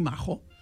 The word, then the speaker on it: majo: Maco és majo. Majo, majo. – Però mal pronunciat. Va entrar al català en l'època dels meus avis, dels vostres besavis, en què els catalans pràcticament no sabien castellà i no sabien pronunciar la jota castellana.